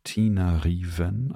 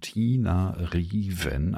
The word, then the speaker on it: Tina Riven: Das heißt Tina Riven, also ist auf Deutsch ausgesprochen, Tina Riven, ein Wort. Tina, wie der Name Tina. Und dann Riven, Tina Riven.